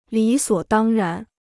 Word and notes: 理所当然: lǐ suǒ dāng rán

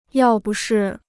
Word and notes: 要不是: yào bu shì

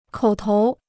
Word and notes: 口头: kǒu tóu

